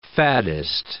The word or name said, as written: faddist